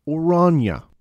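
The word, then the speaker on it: Oranje: I believe it's more properly pronounced Orania.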